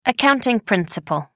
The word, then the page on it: accounting principle: American English